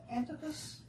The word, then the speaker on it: Antipas: And I was trying to find some information about the Antipas. Is that how you say his name? – Yes, absolutely, yes.